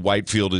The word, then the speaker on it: Whitefield: Whitefield is the pronunciation how it's spelled, from England.